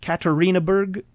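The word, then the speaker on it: Yekaterinburg: Katerinburg, Russia. Is that a fine enough pronunciation there for that word? – That's right.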